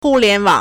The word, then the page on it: 互联网: 互聯網; hùliánwǎng